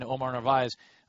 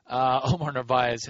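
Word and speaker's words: Narváez: You could have a kind of a budding guy in Omar Narvaez, whose name, by the way, is pronounced differently by everybody who gets to pronounce it. Some people put the R after the V where it actually is, kind of like a Brett Favre situation.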